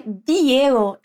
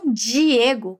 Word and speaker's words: Diego: Percebeu que é Diego e não Diego, como em português?